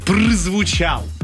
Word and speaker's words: прозвучал: Прозвучал. – Прозвучал, да. – Очень правильное орфоэпия. Прозвучал.